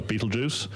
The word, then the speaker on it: Betelgeuse: Betelgeuse star? – You're not a child of the 80s. If you were, you would know the film with a similar name. The star is Betelgeuse.